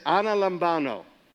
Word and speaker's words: analambano: Everybody say analambano. Spell it just like it sounds. A-N-A-L-A-M-B-A-N-O.